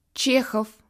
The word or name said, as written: Tchekhov